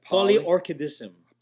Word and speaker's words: polyorchidism: How do you say that? – Polyorchidism. – polyorchidism. See, that's where I was screwing up. I thought it was orchid, but I was like, no, that can't be it.